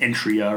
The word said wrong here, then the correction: Eritrea: or whatever. – Eritrea in Africa.